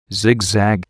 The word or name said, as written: zigzag